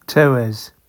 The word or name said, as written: Tewes